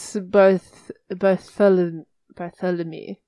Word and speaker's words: Saint-Barthélemy: Saint Barthélemy. I don't know if that's really how you say it. – It's French. It's Saint-Barthélemy.